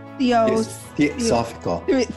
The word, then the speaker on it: Theosophical: A lifetime member of the Theos Physical, did I say that wrong? – Theosophical. – Theosophical Society.